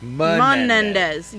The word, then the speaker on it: Menendez: So I've been pronouncing this wrong the whole time. I've been saying Hernandez, and it's Menendez.